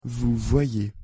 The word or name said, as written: vous voyez